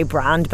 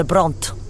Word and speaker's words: Brand: I do actually just say Brand, but he always says Lucinda Brandt. Brandt.